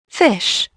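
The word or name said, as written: fish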